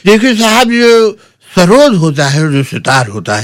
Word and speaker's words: Sarod: Sarod, actual pronunciation was Sarood.